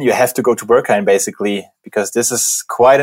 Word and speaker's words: Berghain: So the first one, excuse my German pronunciation, so sorry, Berghain and Panorama Bar. How is that? – Yeah, Berghain.